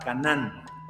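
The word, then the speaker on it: Caná (קנא): Caná, es sin N al final, porque el otro es Canán, donde fluye leche y miel. No, es Caná, Caná, C-A-N-N-A, acento en la A, ese es el, celo del que se refiere el Antiguo Testamento.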